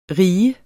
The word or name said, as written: rige